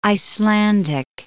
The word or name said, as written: Icelandic